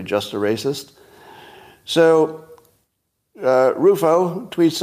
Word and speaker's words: Rufo: R-U-F-O, is it Rufo or Ruffo? Does anybody know? I'd like to say it right. Ooh? Rufo? Okay, I think it's Rufo. But he likes dunking on Ibram Kendi, who are, let's say, what would be the right word?